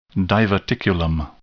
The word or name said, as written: diverticulum